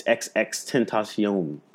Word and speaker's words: XXXTentacion: It's XXXTentacion.